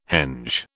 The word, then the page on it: henge: British English